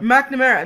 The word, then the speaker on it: McNamara: Anyway, then Michelle McNamara, is that how you say her name? – Isn't that McNamara? – McNamara.